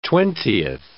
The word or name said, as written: twentieth